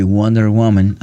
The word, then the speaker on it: Wonder Woman: Viejo, y Wonder Woman, ¿así se pronuncia, hermano? – Así vale.